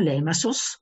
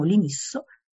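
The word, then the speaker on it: Limassol: Non so se lo sapete, c'è una piccola cittadina, Lemassos o Limassos o Limisso, dipende da come uno poi vuole pronunciare, in italiano la chiamiamo Limisso, che è una cittadina che si trova nella costa nord di Cipro, che è un'enclave russa.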